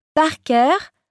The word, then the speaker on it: Parker: Ça s'écrit M-E-N-D-U-L-A. – C. – Moi, c'est Jessica Parker. Parker, P-A-R-K-E-R.